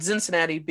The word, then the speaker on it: Zinzinnati: So we have a Bockfest, we have a huge Oktoberfest, but we also have a Krampuslauf Zinzinnati, and Zinzinnati being the German way to pronounce pronounce Cincinnati. So Z I N Z I N N A T I.